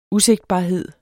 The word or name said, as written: usigtbarhed